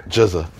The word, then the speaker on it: GZA: Did I say that right? – Yes, you did. – Let me hear you say it just so I can confirm it. – GZA. – I said it right.